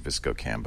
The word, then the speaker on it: VSCO: You don't pronounce it ViscoCam? – I pronounce it ViscoCam, but I'm saying VSCO so people will not think it's Viscous or something like that.